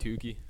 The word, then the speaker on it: Tookey: I'm going to pronounce it Tuki. I mean, two O's, right? It's like two, and then it's spelled T-O-O-K-E-Y. No, but look what I did, though. It's like a compound word. It's like T-O-O, and then K-E-Y, so Tuki. So like Tuki.